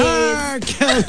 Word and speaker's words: Hercules: Tapos all caps yung her. Her, Hercules. – Hercules. Stress on the her.